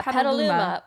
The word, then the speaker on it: Petaluma: That was literally called Rainbow for Petaluma, California. Did I say that right? – Yeah, Petaluma.